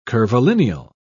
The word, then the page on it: curvilineal: British English